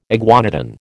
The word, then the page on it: iguanodon: American English